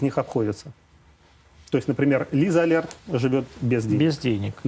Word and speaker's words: Лиза Алерт: Не кажется ли вам, что использовать Лизу, а как правильно говорить, Лиза, Али, Лиза Алерт. Лиза Алерт.